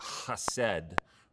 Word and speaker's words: hesed: And um, Hebrew is a guttural language, and you'd literally pronounce it something like hesed, right? Kind of let from the back of your throat sort of feeling.